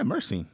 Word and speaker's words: myrcene: What is myrcene? Am I saying that right? – Yeah, myrcene. – Myrcene. – Yeah. Myrcene, M-Y-R, yeah. Scene, C-E-N-E.